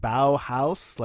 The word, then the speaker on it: Bauhaus: How do you say it? Bauhaus? Like Bow Wow? – Exactly.